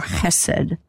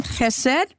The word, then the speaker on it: chesed: And so we want you to have the sound of a het here and not just a plain H. Now, the book that we're offering, it spells it H-E-S-E-D, but it can be with a dot under it. Then people who know this rule will pronounce this with the ch sound for chesed.